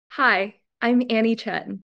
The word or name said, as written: Annie Chen